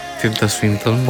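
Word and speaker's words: Tilda Swinton: Δεν ξέρω αν πρόσκεισες την προφορά. – Πάρα πολύ καλά. – Performances. Τίλτα Σουίντον.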